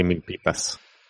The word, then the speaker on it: Milpitas: It's actually Milpitas. So whatever. – Well, I mean, the you know in English they pronounce it you know mil, mil, Milpitas. So I mean that that to me sounds like Milpitos.